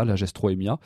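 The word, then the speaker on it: Lagerstroemia: Voilà, Lagestrémia, qui s'écrit Lagestroémia. Hein, donc, souvent, on le prononce aussi comme ça, Lagestroémia.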